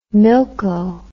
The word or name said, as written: milko